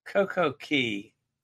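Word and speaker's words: CocoCay: Coco Key, perfect day at Coco K, if you can use their pronunciation.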